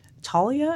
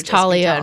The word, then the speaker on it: Thalia: And it's this very pixelated sort of 8-bit looking game where you play as a girl named Talia, I think that's how you would pronounce it. It's T-H-A-L-I-A.